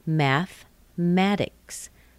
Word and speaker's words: mathematics: It looks like mathematics. Looks like four syllables, but it has three. Listen, mathematics. Mathematics. Very commonly spoken without that E.